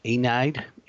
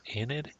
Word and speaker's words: Aeneid: It's called the Aeneid, A-E-N-E-I-D, Aeneid, Aeneid. – Enid. – And it tells a story about a Trojan Aeneas. And it says a quote from the Aeneid says, Adventus fortuna juvat, which again also means fortune favors the bold.